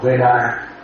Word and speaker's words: Vedana: Nếu theo pháp này thì cái điện thọ mình phải đọc là Vedana ha.